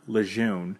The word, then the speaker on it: Lejeune: Now, we understand that there are, you know, there are locals in the community that have grown up pronouncing it Lejeune, and we completely respect that as well.